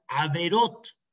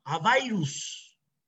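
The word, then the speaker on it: averot (עבירות): En, plural, averot. Pero los hasidim pronuncian avairus. Avairus. Así pronuncian ellos.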